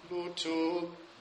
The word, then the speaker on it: lutum: Lutum, right? The accent's on the first syllable.